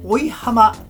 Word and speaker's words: 生浜: 生浜と書いて追浜と読むんですが、その追浜高校の前を通過すると浜の朝鮮 25 を左折とナビが言うんです。